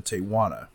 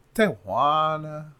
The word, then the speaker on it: Tijuana: In 1989, Guzman sent Armando Lopez, one of his most trusted men, to speak with the Arellano Felix clan in Tijuana. I'm taking it too far with the pronunciation. Tijuana. Tijuana.